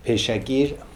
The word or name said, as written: Paischakir